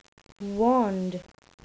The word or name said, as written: wand